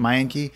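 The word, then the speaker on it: Menke: Do you think it might have something to do with the fact that his longtime editor you know, Sally Mankey, if that's Mankey, is that how you pronounce it? Mankey? – Yeah.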